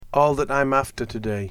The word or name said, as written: all that I'm after today